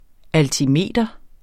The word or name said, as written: altimeter